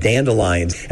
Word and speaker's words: dandelion: So he's saying line and not lion? – Yes. He's taking out a syllable. How do you say it? – Dandelion. – Okay. – Four syllables. Four. Dandelion, yeah.